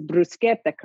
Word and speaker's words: bruschetta: Hello to Joe Cirincione, who probably also pronounces bruschetta correctly as well. – That's which right. – Many people do not. Because you know the difference between a CI and a CH in proper Italian.